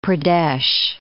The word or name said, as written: Vindhya Pradesh